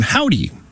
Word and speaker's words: Hovvdy: Here's a brand new one from Howdy. Although it's spelled H-O-V-V-D-Y.